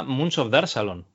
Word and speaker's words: Moons of Darsalon: se llama Moons of Salon Bueno, si lo pronuncio bien. Sí, sí, sí, lo pronuncias bien.